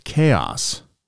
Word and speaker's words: XAOC: It's spelled X-A-O-C. – Yeah. – Apparently pronounced chaos, according to the internets.